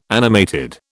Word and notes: animated: British English

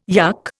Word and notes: yuck: Indian

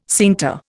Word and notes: scienter: Indian

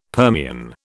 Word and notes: Permian: British English

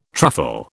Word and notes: truffle: British English

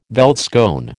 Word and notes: veldskoen: American English

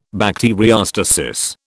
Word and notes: bacteriostasis: British English

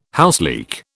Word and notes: houseleek: British English

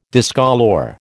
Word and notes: discolour: American English

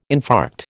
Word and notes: infarct: American English

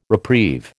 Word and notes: reprieve: American English

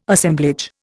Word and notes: Assemblage: Indian

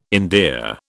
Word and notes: endear: British English

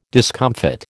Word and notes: discomfit: American English

